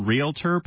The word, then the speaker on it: Realtor: Yeah, like real estate, realtor, okay. – Not realtor.